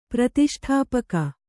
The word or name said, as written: ಪ್ರತಿಷ್ಠಾಪಕ